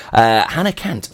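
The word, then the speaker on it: Hanner Cant: And Hannah Chant, pronounced Cant, is Welsh for half a century. Amazing that. Hannah Cant, I love that.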